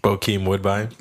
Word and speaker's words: Bokeem Woodbine: There was fundamental flaws, you know, and that's the crazy part, is that there were so many good performances, like Hakeem Woodbine. – Bokeem Woodbine.